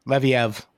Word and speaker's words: Leviev: Like, I think if I Googled him and I saw, okay, Levi, I can't say his name, the diamond, Leviev. – Leviev? – Yes.